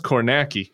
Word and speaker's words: Kornacki: But it's pronounced Kornacki. – Oh, okay. – Steve Kornacki, the gay man with the khakis. Khaki Kornacki. It rhymes.